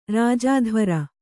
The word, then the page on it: ರಾಜದ್ವಾರ: rāja dvāra